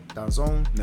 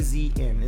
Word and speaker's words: DAZN: DAZN. – Never heard of it. – DAZN.